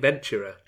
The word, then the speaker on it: Ventura: I think Ventura. Like adventurer, yeah.